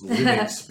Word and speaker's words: Linux: It's Linux because that's how Linus Torvalds said it. Potato. – That's how he pronounced it. – Yeah, so it's not pronounced the same as his name. – His name. – It's actually Linux.